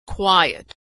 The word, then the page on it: quiet: w